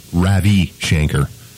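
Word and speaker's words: Ravi Shankar: Got a call from a listener from somewhere who said, yeah, I heard you talking about that Rabbi Shanker guy. Well, you mispronounced his name. His name is Ravi Shanker.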